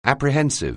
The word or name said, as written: apprehensive